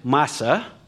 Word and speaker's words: masa: Our English word found comes from a Hebrew word masa. If you'd like to put this in your notes, it's spelled M-A-S-A, masa.